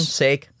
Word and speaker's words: sake: You mean sake? – Sake? – Ugh. Yeah, it's pronounced sake.